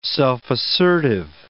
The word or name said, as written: self-assertive